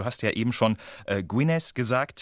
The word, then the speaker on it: Gwynedd: Tatsächlich ist dieses Doppel-D zum Beispiel, also so schreibt sich diese Grafschaft mit 2 D am Ende, spricht man als auf Walisisch als ein weiches TH aus, also Gwynedd.